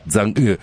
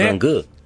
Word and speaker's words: Chang'e: Attenzione, perché da qui a fine anno avremo le nuove missioni lunari cinesi, la Chang'e 7, mi hanno detto che si pronuncia Zhang'e 7, Zhang'e.